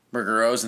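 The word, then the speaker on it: Ruggero: The other main director besides Ruggiero, it's Ruggiero, that's how you say his name, Ruggiero Deodato, yeah, who did, you know, Cannibal Holocaust, stuff like that.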